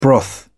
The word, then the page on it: broth: British English